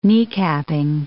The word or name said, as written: kneecapping